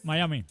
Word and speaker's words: Miami: Perdón, Miami. – Miami.